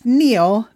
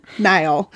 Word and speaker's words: Niall: In the audiobooks, Ronan's dad's name is pronounced Neil, not Niall.